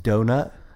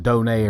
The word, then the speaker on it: Donat: It's pronounced donut. – I actually looked it up to make sure I wasn't, like, it wasn't Donet or something like that.